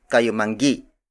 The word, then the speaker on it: Kayumanggi: Number 7 on the list of native Tagalog colors is Kayumanggi.